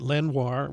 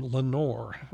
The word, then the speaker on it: Lenoir: In that song, Mayall pronounces it Lenoir, which would be ordinarily correct because it's a French name. But J.B. himself referred to as, well, pronounced his name Lenore.